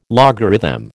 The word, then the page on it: logarithm: American English